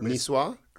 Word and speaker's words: niçoise: It's a French word. – Niçoise? – Yes.